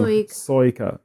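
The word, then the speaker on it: Suica: So I think once you get into the main flurry of stuff, you do, like, I think this, is it Soika? I'm going to say this wrong. – Soika.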